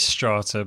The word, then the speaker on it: STR4TA: How are we pronouncing this? Strata? – It's Strata, but they've done that.